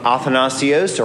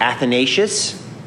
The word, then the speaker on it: Athanasius: Arius was ultimately defeated by another bishop by the name of Athanasius, or Athanasius, as we pronounce it in the West, who argued this.